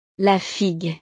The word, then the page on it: la figue: French